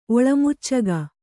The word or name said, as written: ಒಳಮುಚ್ಚಗ